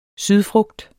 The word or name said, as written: sydfrugt